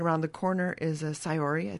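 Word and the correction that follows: saori: I think that's how you say it. – Sorori. – Sorori. – Mm-hmm. Weaving. – Oh, that's a word that does not pronounce like it's written.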